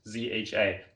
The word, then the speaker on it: Zha: You can just Google my name, Carl Zha. Carl is spelled with a C, so Carl, and my last name Zha. Z is spelled Z like zebra, H like Henry, A like apple. Just three letters, Zha.